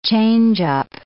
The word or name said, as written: changeup